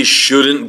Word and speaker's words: shouldn't: Yeah, I really would not strongly pronounce the T at the end of that word. You do hear the N, shouldn't.